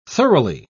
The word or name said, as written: thoroughly